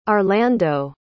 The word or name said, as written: arlando